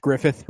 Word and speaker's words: Gruffudd: It's Gruffudd, and it's pronounced Griffith.